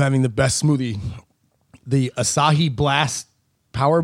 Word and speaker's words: Asahi: Asahi. – I love Asahi. – Closest. That was the closest. – Asahi. – Take two. – Okay. What I'm trying to... Asahi? – Asahi. – Asahi. – Three syllables.